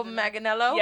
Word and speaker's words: Manganiello: John Maginelli. How do you say his name? Sofia Vergara's Joe Manganiello.